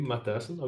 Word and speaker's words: Matthyssen: So, I'm Wim Matthyssen. I will pronounce my back name like I would say it in Dutch.